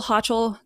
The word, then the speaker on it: Hochul: I think at this point, with all of it, with the military, with hospital workers, I saw that Hochul, the new governor of New York. I don't know how to say her Hochul.